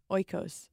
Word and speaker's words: Oikos: Oikos.